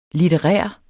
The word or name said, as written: litterær